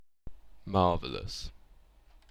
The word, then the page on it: marvellous: British English